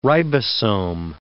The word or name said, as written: ribosome